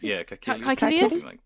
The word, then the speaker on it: Caecilius: Caecilius.